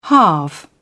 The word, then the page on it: halve: British English